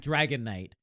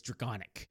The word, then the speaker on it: Dragonic: That's why it's called dragonic. – Yeah. – Not Dragon Knight. It's dragonic so that they can have that sound at the ends to play the things.